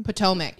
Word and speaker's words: Potomac: Potomac.